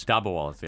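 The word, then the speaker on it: dabbawalas: Dabwalas? – Dabwalas, yeah. – Dabwalas.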